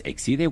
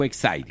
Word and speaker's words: Exide: Se depende como esto, ¿se acuerda que ven unas baterías Exide o Exide? – Exide o Exide. – Como quiera. Ormuz u Ormuz. Dentro se escribe Exide, se pronuncia Exide.